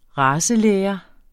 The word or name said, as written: racelære